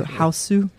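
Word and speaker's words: Hausu: It's H A U S U Houseu.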